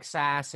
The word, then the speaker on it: Sasse: Ben Sasse, yep.